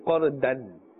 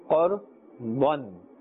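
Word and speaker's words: قرضا: Therefore, we are not saying Qardan. We are saying or pronouncing with a full mouth. Qardan.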